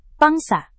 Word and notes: pangsa: Indonesian

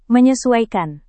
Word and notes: menyesuaikan: Indonesian

